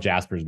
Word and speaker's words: Jaspers: So I like to talk about sort of the defense mechanisms defined by Anna Freud and how those go into what Jaspers, which is spelled J-A-S-P-E-R-S, it's spelled Jaspers, but it's pronounced Jaspers.